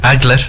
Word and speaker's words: aglet: They don't pronounce the T's usually in French, but anyway. – Oh, so now we still have to be a smart arse. – Aglet, Peter. It's aglet.